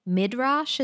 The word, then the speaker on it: Midrash: Midrash, is that how you pronounce it? – Yeah.